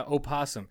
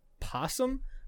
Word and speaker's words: opossum: Yeah, opossum. And what's weird is that I always thought it was just possum, but you can pronounce it either way.